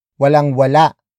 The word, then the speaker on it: walang wala: Walang wala, akong, pagkain.